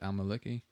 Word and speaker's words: al-Maliki: He goes on to say, to give al-Maliki... I don't even know how to pronounce that. Al-Maliki? – That's about right.